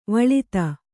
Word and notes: ವೇಲ್ಲಿತ: vēllita

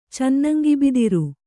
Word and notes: ಚೆನ್ನಂಗಿಬಿದಿರು: kannada

